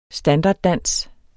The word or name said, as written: STANDARDDANSENE